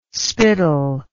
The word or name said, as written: spital